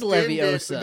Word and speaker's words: Leviosa: You point your wand, but you forgot, or you said Leviosa, not Leviosa. – No, it is Leviosa.